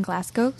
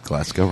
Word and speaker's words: Glasgow: It's from Alan McKay in Glasgow, Scotland. Did I say that right? – Yes. You got Glasgow right.